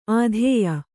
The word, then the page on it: ಆದೇಯ: Kannada